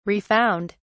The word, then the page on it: refound: British English